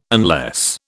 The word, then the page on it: unless: British English